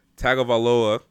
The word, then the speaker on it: Tagovailoa: Alabama quarterback Tua Tagovailoa declared himself eligible.